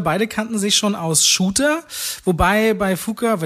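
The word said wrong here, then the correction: Fuqua: wenn er überhaupt richtig so ausgesprochen wird, das so ein Hin und Her Fukua, ist. Fukua, glaube ich, er ist Fukua.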